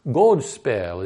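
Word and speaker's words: godspel: Godspell is how you would pronounce it in old English.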